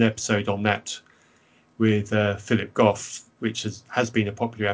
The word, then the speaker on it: Goff: So basically everything I've said is a form of religious fictionalism, and I really admire Philip, is it Goff you pronounce yes.